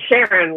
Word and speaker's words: Sharon: It's Sharon, like Sharon. – Karen.